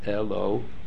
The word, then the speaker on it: Eloah: So the correct pronunciation is eloah.